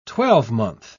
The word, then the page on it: twelvemonth: British English